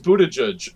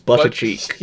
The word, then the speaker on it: Buttigieg: It's apparently pronounced Buttigieg, or Booty Judge, as somebody called it. – Oh, God. – Pete Buttigieg. – I've been reading it as Buttercheek. – Yeah. But it is apparently Buttigieg.